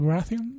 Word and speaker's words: Baratheon: Baratheon.